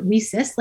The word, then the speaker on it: WiCyS: We pronounce it WSIS, like we sisters.